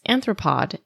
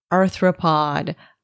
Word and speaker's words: arthropod: Throughout my research, I kept misspelling the word arthropod as anthropod, and I even put a note to myself to not mispronounce it, and I stubbornly did it anyway. Arthropod, arthropod, arthropod.